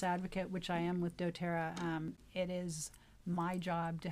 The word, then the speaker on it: doTERRA: And then your specific company, doTERRA, did I say that right? – Yes. – doTERRA.